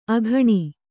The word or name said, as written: ಅಕ್ಕಣಿ